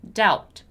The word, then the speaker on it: doubt: And the word doubt, although it's spelled D-O-B-T, it has a very peculiar, a very particular pronunciation, right? It's doubt, doubt, doubt.